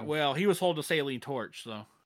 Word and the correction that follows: acetylene: It's pronounced acetylene torch. – Acetylene. – Acetylene torch. – Acetylene torch. Okay.